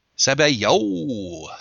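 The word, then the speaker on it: sabayon: Yeah, it's French, and what it said was, Sabayo. Sabayo. – Okay. Sabayo. – So let's try to get it right from now on. It's Sabayo.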